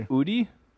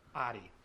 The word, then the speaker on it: Audi: Udi? – Audi.